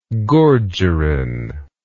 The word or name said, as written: gorgerin